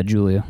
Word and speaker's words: Giulia: Yeah, Giulia. Yeah. – I've always wondered that. – Yeah. Usually okay. You think it's with a J, but this has a G in it and some I's and stuff.